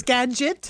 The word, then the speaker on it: Skagit: Oh, it's Skagit? – Skagit, yeah.